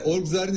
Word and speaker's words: Orgzar: Orgzar'ın ismini doğru söylüyor muyum? Doğru telaffuz ediyor muyum? – Evet, doğru telaffuz ediyorsunuz.